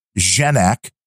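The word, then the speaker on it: Genack: But he corrects me that his last name has a soft G like generic. So it's Brian Genak, not Gen.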